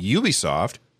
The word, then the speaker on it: Ubisoft: That's how we can remember how to pronounce Ubisoft, is by saying Ubisoft, you can do it.